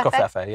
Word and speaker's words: Covfefe: Margot says, hi, Pope Covfefe. Am I saying that right? – I believe it's Covfefe, yeah.